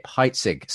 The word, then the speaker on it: Heitzig: It's Justin Briley with you for The Profile today, and in conversation with Skip Heitzig.